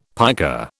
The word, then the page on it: piker: British English